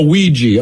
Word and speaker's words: Ouija: So it obviously, it's I mean, that's why we have to go like, how do you pronounce that again? Oh, Ouija.